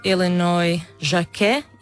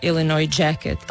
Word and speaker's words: Illinois Jacquet: Ono što ćemo sad čuti, meni je osobno omiljena verzija te pjesme i radi se o tenor saksofonistu Illinois Jacquet ili Illinois Jacquet. On je bio Amerikanac koji je imao francusko prezime i s vremenom je prihvatio i engleski izgovor Illinois Jacquet, tako su ga zvali.